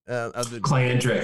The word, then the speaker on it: Clientric: And did I say the name right? – Clientric. It was close. – Man, I was playing it in my head as you said it, Clientric.